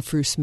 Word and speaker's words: Froose: And then we have this little mascot, Milton the Froose Moose. So it's a moose oh, and it I rhymes with fruit.